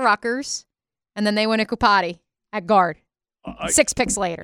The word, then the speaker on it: Iupati: Iupati.